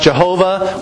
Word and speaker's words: Jehovah: YHWH, which can be pronounced Jehovah or Yahweh.